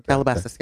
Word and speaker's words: Calabasas: Calabasas. – Is that how you say it? Yeah. – Calabasas. Yeah. Mm-hmm. You got it.